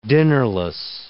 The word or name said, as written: dinnerless